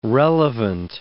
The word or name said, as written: relevant